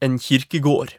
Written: en kirkegaard

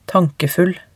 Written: tankefull

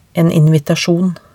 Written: en invitasjon

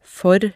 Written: for

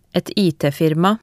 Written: et IT-firma

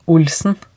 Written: Olsen

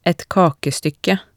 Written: et kakestykke

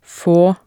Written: få

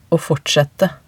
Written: aa fortsette

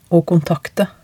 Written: å kontakte

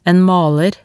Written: en maler

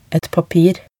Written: et papir

